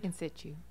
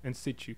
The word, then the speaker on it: in situ: Am I saying it right? – In Situ. – In Situ. So it's a softer T.